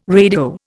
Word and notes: radio: Indian

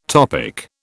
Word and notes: topic: British English

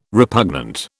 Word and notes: repugnant: British English

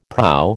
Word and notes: proa: American English

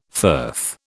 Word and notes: firth: British English

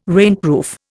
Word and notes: rainproof: Indian

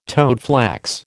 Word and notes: toadflax: American English

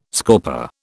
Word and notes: scorper: British English

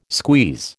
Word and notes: squeeze: American English

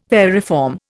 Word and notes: variform: Indian

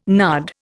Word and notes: nard: Indian

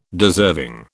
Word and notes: deserving: British English

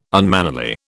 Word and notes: unmannerly: British English

